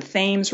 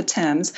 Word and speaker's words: Thames: It's pronounced the Thames River here, not the Thames.